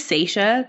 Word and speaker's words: Sasha: So, again, my name is Sasha Grabenstetter. You're wondering how to say Sasha. That's how you say it.